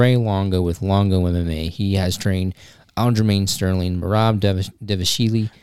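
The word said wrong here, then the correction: Dvalishvili: Dwalashvili, Matt Frivola. – The V's are W's if you pronounce it like that.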